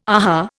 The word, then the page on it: aha: Indian